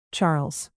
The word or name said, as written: charles